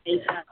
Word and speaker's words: Asaph: The son, I'm not pronouncing his name correctly, Asaph. Amen, amen. – Asaph.